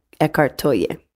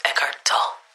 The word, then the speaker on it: Eckhart Tolle: And the person who said that is Eckhart Tolle. Mm. I hope that's how you pronounce his name. – Greetings. That is not, in fact, how you pronounce his name. It's Eckhart Tolle.